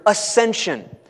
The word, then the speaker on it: ascension: Uh, another word that we could use to describe this glory, this super exalted reality, is the word ascension ascension. So we have the resurrection, but we also have the ascension of Jesus. Uh, AS silent C.